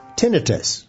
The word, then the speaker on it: tinnitus: Tinnitus, or tinnitus, I guess is also correct pronunciation, also ringing in the ears.